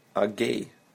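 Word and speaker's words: Аггей: Агей. Агей. Гей. Агей. Notice double letter G. Аг. Агей. Good.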